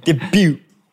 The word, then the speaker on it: debut: That's… T is silent. – Debut.